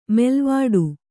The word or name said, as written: ಮೇಲ್ವಟ್ಟ